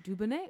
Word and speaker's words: Dubonnet: Is that how you say it? – Yeah, Dubonnet. You're the French one. – Oh yes, I believe it is Dubonnet.